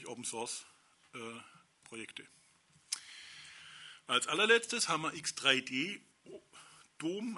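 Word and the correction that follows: X3DOM: also X3 Dom, spricht sich X3 Dom aus, also X-Freedom soll, man, soll das bedeuten wohl.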